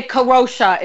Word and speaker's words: Kadrolsha: You can just say Kadrolsha if you want to. It might be easier. – Kadrolsha? – No, that sounds like corrosive. No. Say it properly.